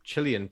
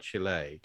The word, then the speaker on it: Chile: I think somebody somewhere heard Chilean people, got sent over there, heard Chilean people talk about Chile. – Chile. – Chile. – Chile. – Chile. – And started pronouncing it that way.